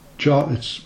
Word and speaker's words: Jervis: It's a place called Jarvis. It's not actually Jarvis Bay, it's the promontory of rocks that stand at the entrance to Jervis Bay, to Jervis Bay, as it's spelt, on the South Wales coast.